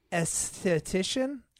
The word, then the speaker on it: esthetician: She is going to school to obtain her esthetician? – Yeah. – License? Did I say it right? – Mm-hmm.